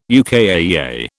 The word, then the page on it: UKAEA: British English